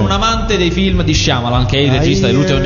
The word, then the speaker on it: Shyamalan: Si dice Shyamalan. – Ma non c'è una I. – Ho capito, ma si pronuncia così. Come io.